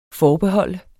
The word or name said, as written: forbehold